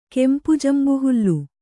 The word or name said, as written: ಕೆಂಪುಜಂಬುಹುಲ್ಲು